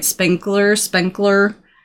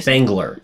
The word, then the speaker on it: Spengler: Spenkler. But obvious. – Spengler.